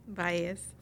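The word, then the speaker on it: Vélez: Okay, Eileen, how do you say it in Spanish, the last name? – Baez. – Baez in Spanish.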